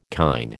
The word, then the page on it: kine: American English